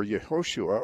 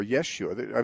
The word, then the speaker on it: Yeshua: Okay, Jesus is the same name as Joshua, but Joshua wouldn't have been pronounced Joshua by the Jews either. They don't have a J sound in their language. So, right. – His name would have been Joshua or Yehoshua or Yeshua.